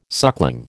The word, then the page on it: suckling: American English